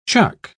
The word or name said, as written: chuck